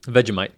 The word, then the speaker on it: Vegemite: So Jessica Haley said, Vegemite, am I pronouncing that right? – Vegemite.